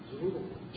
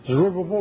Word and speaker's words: Zerubbabel: Zerubbabel. – Zerubbabel or Zerubbabel, either one. Like Rubble, you know, like Rubble, Zerubbabel. And who else? Speak to two people. – Joshua. – Joshua, the high priest. So Zerubbabel, the chief ruler, and Joshua, the high priest.